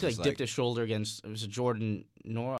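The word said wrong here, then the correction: Nwora: How do you pronounce that name? – Was it Jordan Wara? – Wara, right? And then just slammed it down over Enoch.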